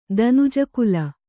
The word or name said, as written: ದನುಜಕುಲ